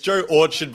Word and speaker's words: Joe Orchard: It's Joe Orchard, by the way.